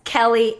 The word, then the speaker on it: Kellie: Jess Kelly Adams, and Kelly is spelled K-E-L-L-I-E.